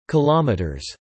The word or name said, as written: kilometers